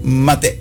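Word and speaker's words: mate (מטה): Mate, con acento, no lo lleva, pero en el hebreo no hay acento. Es la pronunciación mate.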